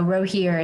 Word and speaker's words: Rogier: I know Rogier is correct without the appropriate rolling of the tongue that I probably could not muster, but it'll either be van der Weyden, van der Weyden.